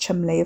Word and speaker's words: Cholmondeley: The senior Mr. Cholmondeley, pronounced Chumley, of course, in the way that Featherstonehaugh was pronounced Fanshawe, had never failed in this respect, although as far as Wes could tell he'd failed in almost every other.